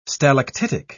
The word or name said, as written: stalactitic